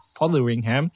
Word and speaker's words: Fotheringham: Und dann, weil du dieses, weil du dieses TH wieder hast, ne, musste immer Fotheringham. Mhm. Ich habe das wirklich die ganze Woche geübt, damit ich es hier heute ordentlich ausspreche. Mark Fotheringham.